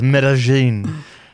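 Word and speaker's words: Medellín: Well, I've been to, it's spelled, and I called it when I went there the first time, Medellin, and it was like laughed off the face of this earth, but it's Medellin.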